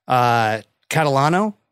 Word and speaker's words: Catalano: Alex, Catalano.